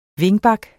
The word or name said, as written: wingback